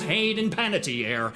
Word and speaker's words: Hayden Panettiere: Chloe Sevigny, Mariska Hargitay, and George Takei, Richard Ayoade, and I won't even dare try to pronounce Hayden Panettiere.